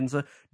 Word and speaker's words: Ginza: I'm pronouncing Ginza, that correct? Yeah. Ginza. During that sequence when they're like, what is that monster?